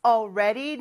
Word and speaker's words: already: 발음이 조금 어렵죠. All 하면서 혀가 윗니 뒤쪽에 와서 딱 붙어야 돼요. 그런데 R 발음하면서는 이제 떨어져야 되는 거죠. Already. 요걸 빨리 하면 Already가 돼요. 근데 사실 L 발음하면서 딱 붙어야 하지만 빨리 발음할 때는 가다가 말기도 합니다. 그래서 꼭 붙여야 될 필요는 없어요. 이 경우는 R 발음할 때 떼야 되니까 그게 조금 빠르더라고요.